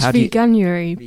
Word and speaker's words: Veganuary: It's veganary. Is that, am I saying that right? Veganary? – I thought it was veganuary. But veganuary. however you want to... Well, that's part of the problem with their branding. No one quite knows how to say it.